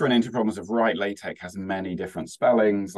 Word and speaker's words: LaTeX: Like LaTeX, I'm pronouncing it with the soft sort of k sound at the end. But when you write it, it's L-A-T-E-X. It's just that the X is a chi, and so hence the k sound.